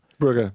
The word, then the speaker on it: Brugge: Brugge. – Brugge, yeah.